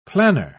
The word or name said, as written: planner